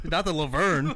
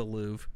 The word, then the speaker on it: Louvre: Yeah, not the Luhvere. – No, I didn't say urn. Luhvere is what I said. – It's just the Louvre. – Oh, is it really? No R? – You don't pronounce it.